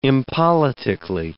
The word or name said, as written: impoliticly